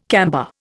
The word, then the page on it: camber: Indian